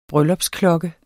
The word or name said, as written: bryllupsklokke